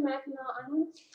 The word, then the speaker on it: Mackinac: Answer Mackinac Island. – That's how you pronounce it, yes. I would have given you credit had you said Mackinac, but you pronounced it correctly.